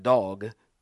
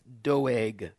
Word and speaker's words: Doeg: Looks like dog, but it's actually pronounced Doeg and he is an Edomite, the chief of the herdsmen who belonged to Saul.